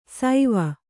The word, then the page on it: ಸೈವ: saiva